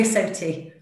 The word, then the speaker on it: SOTI: We have Sales and Marketing Director of SOTI. I don't know if I pronounced that correctly. – Well, actually, some people do pronounce it that way. I say SOTI.